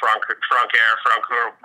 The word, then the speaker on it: Francoeur: But in English, Francoeur works just fine.